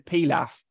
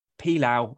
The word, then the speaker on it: pilaf: I, I, have to admit, before when I saw this story, I had to look up the pronunciation of pilaf because it's one of those where sometimes you go to an Indian restaurant, it's pilaf, pilau. I wasn't sure but it is Pilaf apparently.